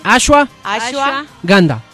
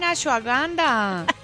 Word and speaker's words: ashwagandha: A ver, repitan conmigo, niñas. – No, yo dije, ya mira, ashwagandha. No. – Así no se dice. – No, la de Akawanda, acuérdate. Así, la de Wakanda. – La Wakanda forever. – Repitan conmigo, niñas. – Señor. A ver. – Ashwagandha. – Ay, lo dije bien, ashwagandha.